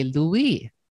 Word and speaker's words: Louis: I just realized that I am pronouncing Maha's last name with the American way of doing it. Here we're speaking about language, and Maha's last name is pronounced in the French way, Louis. – Yes. – So Mahaleen Louis.